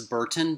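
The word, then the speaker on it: Bertin: It is Burton, but it's spelled oddly. It's B-E-R-T-I-N. So I believe you said Burton. You would be correct. – Okay. – It's just not the typical B-U-R-T-O-N.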